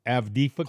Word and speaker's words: Avdiivka: What these soldiers here, they were protecting a city called Avdiivka. I hope I pronounced that right. Avdiivka. – Close enough. – Avdiivka.